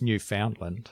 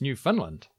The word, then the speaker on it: Newfoundland: This is the first thing, because I've always thought it was Newfoundland. – Of course you did. And apparently I've been saying it wrong, because when you're from Newfoundland, it's Newfoundland, which is up there in a little island.